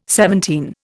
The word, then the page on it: seventeen: Indian